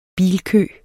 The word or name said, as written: bilkø